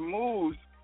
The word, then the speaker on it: Muse: Muse. It's spelled M-U-S-E, but it's spelled or it's pronounced Muse.